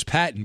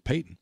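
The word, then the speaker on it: Paton: I thought it was Patton, but apparently he pronounces it Payton.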